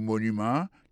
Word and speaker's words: monument: Pour mot monument, il est tombé sur le premier syllabe là.